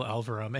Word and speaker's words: Elverum: And she made friends with Phil Elverum. Is that how you pronounce his name? – Yeah. He's changed the spelling, but it's Phil Elverum, and who was more or less kind of living at the studio at that point in time.